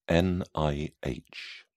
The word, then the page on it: nih: British English